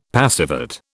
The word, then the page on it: passivate: British English